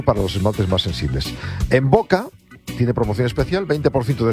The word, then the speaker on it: Mvoca: Envoca se escribe M-V-O-C-A.